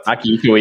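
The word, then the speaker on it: Maki Itoh: I can't ever pronounce her name right, but Maki Ito, yeah. – Maki Ito.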